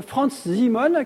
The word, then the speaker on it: Franz Simon: Et c'est ainsi qu'il recrute Franz Simon, qui en allemand se prononce ainsi, j'espère, et qui sera plus tard Sir Francis Simon en anglais.